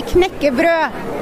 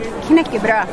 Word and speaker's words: knekkebrød: And in Norway, we say, here you say crisp bread, we say knekkebrød, which is break bread. Knekkebrød. – Okay. So for those who don't know how to say that, it's K-N-E-K-K-E-B-R-O-D.